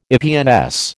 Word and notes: EPNS: American English

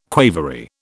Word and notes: quavery: British English

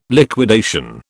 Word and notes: liquidation: British English

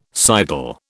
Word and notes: sidle: British English

